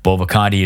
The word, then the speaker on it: Bovaconti: Bovaconti is spelled B-O-V-A-C-O-N-T-I.